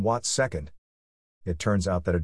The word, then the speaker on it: watt second: That's pronounced watt second, not watts per second.